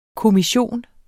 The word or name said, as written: kommission